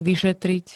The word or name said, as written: vyšetriť